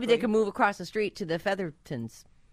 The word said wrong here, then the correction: Featheringtons: Is that their thing? Feather? – Yeah, I think that's how you say it. – Featheringtons? Oh, Featheringtons. That's it.